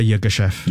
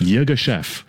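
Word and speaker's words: Yirgacheffe: But is that how you could pronounce it? Say that again. – Yeah, Ethiopia Yogeshef. – Yogeshef. – That's right.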